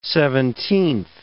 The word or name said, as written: seventeenth